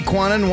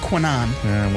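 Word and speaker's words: Kwannon: Now, Free Quanon wants to be called Psylocke. – I think it's pronounced Quanon. – Whatever.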